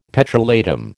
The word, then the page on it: petrolatum: American English